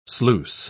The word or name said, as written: sluice